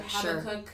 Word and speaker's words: Habakkuk: The thematic reading is from is it Habakkuk? I don't. – That's how I pronounce sure, it. Habakkuk. Sure.